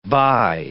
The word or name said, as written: bi